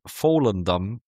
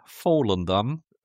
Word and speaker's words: Volendam: Feyenoord hat nur 0-0 in Volendam gespielt. – Oh, das klang jetzt aber sehr professionell ausgesprochen. Sag nochmal. – Herzlichen Volendam.